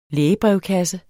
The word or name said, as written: lægebrevkasse